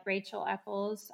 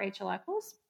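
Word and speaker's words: Rachael Eckles: I do have Twitter and Facebook, so you can find me on Instagram and Twitter at Rachel Eccles. My name is spelled a bit uniquely. It's RACHAEL. ECKLES. So I'm at Rachel Eccles.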